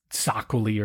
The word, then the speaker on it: Txakoli: It's spelled TXAKOLI, but Julian the wine guy said it's Txakoli.